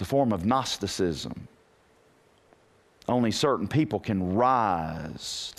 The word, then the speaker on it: Gnosticism: Notice the letter in front of it. It's silent. Don't pronounce the G. You'll look stupid. Gnosticism. Mm-mm. It's like pneumonia. Don't pronounce the P. Pneumonia. You know, it's pneumonia. P silent, G silent, and Gnosticism.